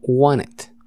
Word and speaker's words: wanted: Entonces se pronuncia como si no pronunciaras la T. Es decir, como want it.